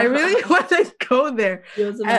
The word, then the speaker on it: Yosemite: Wait, you said Yosemite? Yeah, because and I Google and they're like, that is not how you say it.